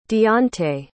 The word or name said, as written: dionte